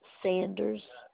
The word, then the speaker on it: Sanders: M-U-R-A-H. I ordered U-R-A-H. And that last name? – Sanders. – Sanders is spelled S-A-N-D-E-R-S.